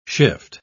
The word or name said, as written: shift